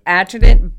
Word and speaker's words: adjutant: Adjutant.